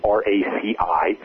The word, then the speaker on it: RACI: It's called RACI or RACI, for two different pronunciations, but it's RACI.